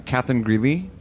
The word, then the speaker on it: Catherine Greeley: But at first, we have uh, Catherine Greeley from the Heritage.